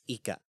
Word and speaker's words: Ykka: It's odd spelling. It's YKKA. – Yeah. – It's Ika.